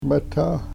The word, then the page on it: metah: Yurok